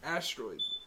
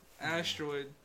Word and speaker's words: Astero!d: Asteroid. – All right. Self-titled. – SoundCloud. Asteroid. Username. Asteroid. – How you spell it? – The only A-S-T-E-R-O, it's not an I, it's an exclamation point, and then a D.